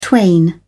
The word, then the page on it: twain: British English